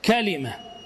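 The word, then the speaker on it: كَلِمة: أما لغاتها فكلمة كما ننطقها نحن، كلمة على وزن نبقة.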